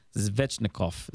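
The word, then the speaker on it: Svechnikov: So Zvechnikov is the name.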